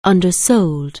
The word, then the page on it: undersell: anglais